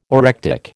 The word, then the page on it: orectic: American English